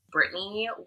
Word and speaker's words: Brittnie: Main Instagram is Brittnie Wick _ fitness. My name is spelled weird, so it is INIE. There is no A.